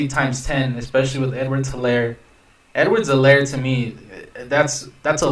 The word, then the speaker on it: Edwards-Helaire: but I think Edwards-Helaire, the H is silent. I just learned that.